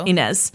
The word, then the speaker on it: Ynez: And so Graham gets in the car with him and they drive out to the Santa Ynez Mountains, Y-N-E-Z. How do you think that's pronounced? Inez. So they drive out to the Santa Inez Mountains and they hike up this trail called the Lizard's Mouth Trail.